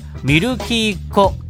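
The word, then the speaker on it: 美新人娘: ちなみにミルキーっ子というのは漢字 4 文字です。美しい、新しい、人、娘と書いてミルキーっ子と読ませるものです。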